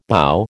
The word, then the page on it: bough: American English